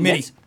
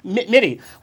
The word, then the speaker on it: Mitte: Mitte. Mitte, where a portion of the proceeds uh, will benefit the United Cerebral Palsy Foundation.